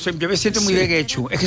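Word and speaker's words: Getxo: En Guecho, que nunca sé cómo se pronuncia. – ¿Guecho? – Guecho. Yo creo que lo pronunció bien. – Muy bien.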